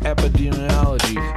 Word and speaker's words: epidemiology: I can barely pronounce epidemiology.